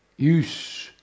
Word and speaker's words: ius: Quegli usci, ius, da pronunciare con una bella e forte U lombarda, premuta proprio come un clacson, erano ado ant.